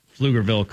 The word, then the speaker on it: Pflugerville: I'm proud of you for pronouncing Pflugerville correctly.